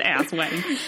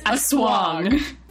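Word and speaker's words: Aswang: Julia, it's not pronounced ass wang. – It's a swong.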